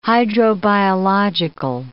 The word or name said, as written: hydrobiological